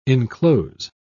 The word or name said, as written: enclose